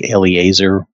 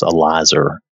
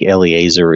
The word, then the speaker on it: Eleazar: From what I could find quickly, it seemed like Eleazar was the correct pronunciation. There was another pronunciation that was Elizer. I'm not sure that it matters that much, but I think Eleazar is probably correct.